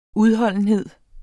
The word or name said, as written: udholdenhed